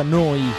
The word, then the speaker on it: Neu: si scrive Neu, si pronuncia Noi e vuol dire nuova, nuova emittente urbana.